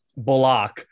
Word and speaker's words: Bullock: That's my guy, but we've changed the pronunciation of his name now. Bullock.